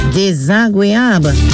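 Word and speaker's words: dézagwéyab: Desagreable. It is spelled D-E-Z-A-G-W-E-Y-A-B. That is D-E-Z-A-G-W-E-Y-A-B.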